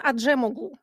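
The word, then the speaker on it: Acemoğlu: Даран Аджемоглу, кажется, так правильно надо произносить его фамилию, человек интересный.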